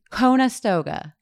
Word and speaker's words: Conestoga: How do you pronounce it? – Conestoga. – Conestoga? – Conestoga. It is.